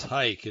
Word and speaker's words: Haik: Now, the first name of this first son is Hike, and that's very interesting because I know a woman, an Armenian woman, whose son's name is Hike. It's spelled Haik.